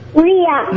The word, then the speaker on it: Rhea: Is it Mia? – Ria. – Ria. Oh, it's Ria. Okay. Thank you, Ria. – R-H-E-A. – R-H-E-A. Oh, thank you for spelling that out.